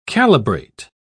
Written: calibrate

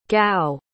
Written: gow

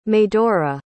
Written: medora

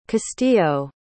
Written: castillo

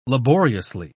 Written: laboriously

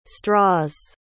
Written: straws

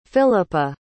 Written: Philippa

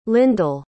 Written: lindell

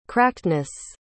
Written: crackedness

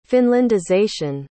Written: finlandization

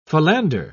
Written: philander

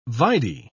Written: vide

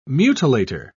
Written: mutilator

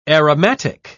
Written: aromatic

